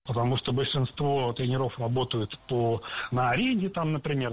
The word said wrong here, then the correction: тренеров: Тренеров, неверно. Перед нами, опять же, слово с неподвижным ударением. И двигать это ударение мы не должны. Всегда ударяем «е» в первом слоге. Тренеры, тренера.